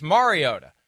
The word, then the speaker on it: Mariota: It's Mariota.